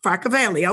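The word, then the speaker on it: Flockaveli: Flockavelly. Did I pronounce that right, brother? – Flockavelly.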